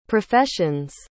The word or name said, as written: profession's